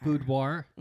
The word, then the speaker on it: boudoir: Boudoir. Mm-hmm. War with an R.